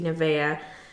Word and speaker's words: Nevaeh: Which I think it's supposed to be Nevaeh, like if you actually pronounce it right. Nivea, the lotion brand? – Oh my gosh. But, which is heaven spelled backwards, whatever.